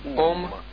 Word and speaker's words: Aum: This mantra also is called Om, but it's pronounced as Aum.